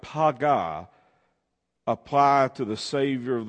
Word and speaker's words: paga: It's a word that is pronounced paga, P-A-G-A in the Hebrew.